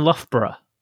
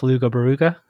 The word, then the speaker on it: Loughborough: Also, Loughborough is the one that Americans always pronounce Luga Baruga. Because it's how it looks. L-O-U-G-H-B-O-R-O-U-G-H, Luga Baruga.